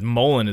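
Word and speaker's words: Mullein: No, she didn't show me where to shit, but she showed me what Mullen, I was saying it wrong, but Mullen is what it is.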